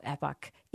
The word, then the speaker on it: epoch: And in the book, you say, Jesus initiated a new historical epoch. How do you say that? Epoch. – I say epic, but you know when you epic.